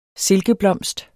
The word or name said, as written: silkeblomst